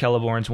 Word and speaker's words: Celeborn: There's two different Celeborns. One is a tree, and one is an elf. – But they're pronounced the same. – Yes, as far as I know.